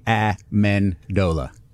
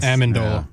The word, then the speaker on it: Amendola: Danny Amendola as well. I'm struggling with that name. How do you pronounce Danny's name? – Amendola. – Amendola, I thought. – Amendola.